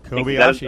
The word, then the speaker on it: Kobayashi: Kobayashi. – Kobayashi.